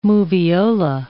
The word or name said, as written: Moviola